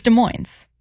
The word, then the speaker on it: Des Moines: It's Des Moines.